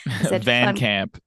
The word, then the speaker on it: Van Camp: My South African nature, I literally butchered the American pronunciation of your surname. – Van Kamp.